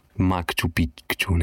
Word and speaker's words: Machu Picchu: In je res ful strmo, no, in pa priješ gor in pa lepo dol vidiš Machu Picchu. Oziroma pravilne je Machu Picchu, neki tazga, pravilno, se izgovori.